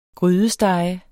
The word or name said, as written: grydesteg